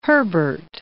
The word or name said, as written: Herbert